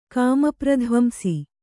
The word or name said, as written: ಕಾಮಪ್ರಧ್ವಂಸಿ